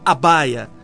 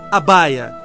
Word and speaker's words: abhaya: Por isso essa palavra abaya, que se escreve no nosso idioma A-B-H-A-Y-A, abaya, significa o destemor daquele que está possuído pelo amor.